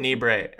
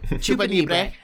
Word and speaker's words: Chupanibre: Chupanibre. – Chupanibre.